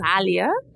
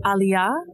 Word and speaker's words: Aliyah: So my name, I pronounce it Aliyah, but the correct pronunciation, it's an Arabic name. In Arabic, it's pronounced Aliyah, and it means elevated, exalted. In Hebrew, it's pronounced Aliyah, and it means a journey to a promised land.